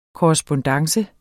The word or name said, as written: korrespondance